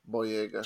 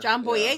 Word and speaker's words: Boyega: Boyega. – John Boyega.